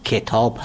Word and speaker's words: کتاب: به طور مثال تلفظ درست واژه کتاب، کتاب هست، یعنی با کسره کاف تلفظ میشه نه با فتح.